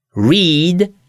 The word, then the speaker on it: read: I teraz uwaga, czasownik, którego pisownia jest taka sama w trzech formach, ale w przeszłym czasie, czyli ta środkowa forma, inaczej jest wymawiana. Read, read, read i w trzeciej formie także. A więc pisownia jest taka sama, inaczej się wymawia.